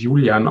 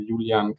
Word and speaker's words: Julian: Ich werde tatsächlich Julian ausgesprochen, aber das ist überhaupt kein Problem. – Ah, echt? Okay, alles klar. ja, ich ähm, werde es tatsächlich öfter mal gefragt, wie man das eigentlich ausspricht, weil ich auch italienischen Hintergrund habe und so. Also ähm, genau, Julian, ganz simpel.